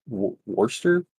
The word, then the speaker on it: Worcester: But unfortunately for HMS Worcester, I guess Worcester? – Worcester.